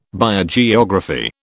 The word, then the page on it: Biogeography: British English